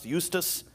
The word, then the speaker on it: Eustace: It looks like justice, but it's pronounced Eustace.